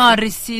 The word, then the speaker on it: Morrissey: Cioè, in una canzone addirittura di Morise- Morisei, ho detto giusto. Morrissey degli Smiths.